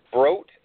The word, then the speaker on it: Brott: Brott? – It is Brott like boat.